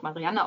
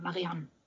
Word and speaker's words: Marianne: Ähm, jetzt muss man natürlich dazu sagen, du hast es ja schon sehr schön ausgesprochen, der Name ist französisch und es wird nicht Marianne ausgesprochen, sondern Marianne.